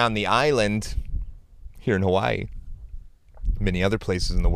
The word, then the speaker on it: Hawaii: Hawaii. That's how it's pronounced.